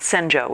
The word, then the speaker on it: Senjo: Only she's called Senjo, which is the Japanese pronunciation of her name in here.